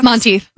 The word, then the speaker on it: Monteith: Monteith.